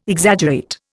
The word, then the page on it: Exaggerate: Indian